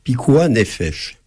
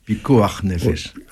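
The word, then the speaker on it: pikuach nefesh: Alors il y a un principe dans le judaïsme, pikuah nefesh, sauvegarde de la vie. – Pikuah nefesh.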